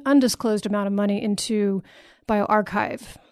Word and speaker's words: bioRxiv: And so BioArchive is spelled B-I-O-R-X-I-V is BioArchive.